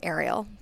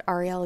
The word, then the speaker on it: Ariel: My parents pronounce my first name Ariel, so you have it right. – Okay. – But Ariel is fine.